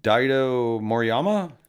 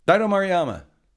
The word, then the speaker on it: Daido Moriyama: But we went to Daido Moriyama. Correct. Did I say that right? – Yeah, you did. – Daido Moriyama.